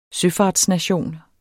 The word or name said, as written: søfartsnation